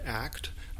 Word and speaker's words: ACT: Well, it's usually pronounced as one word, ACT, although you hear it either way.